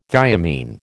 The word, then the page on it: thiamine: American English